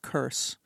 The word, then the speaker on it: Kurs: The name was changed at the border because the name of my great-grandfather was Kurs, K-U-R-S, which was a Polish, common Polish Jewish name. And he was told on the boat on the way over that the name in English would be pronounced curse, and what that meant.